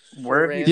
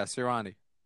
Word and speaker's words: Sirianni: Nick Sirianni. Yeah, the guy from Indianapolis. Yeah, Sirianni.